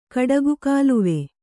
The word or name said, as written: ಕಡಗುಕಾಲುವೆ